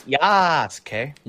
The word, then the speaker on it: Yasuke: Yeah, it's Yasuke.